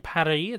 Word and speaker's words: Paris: Don't forget, you have to pronounce it Emily in Paris. It's supposed to rhyme.